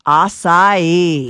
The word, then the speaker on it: Acai: The mispronunciation, people see this and they say acai. – Uh-huh. – Correct pronunciation, acai.